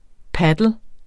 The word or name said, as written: padel